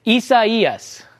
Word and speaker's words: Isaias: This is how you say it. Isaias. All right. I know it's a little tough to say, but that's the proper pronunciation.